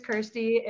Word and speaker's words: Kirstie: Yeah, so my name is Kirstie. It rhymes with thirsty to make it easier for everyone to remember.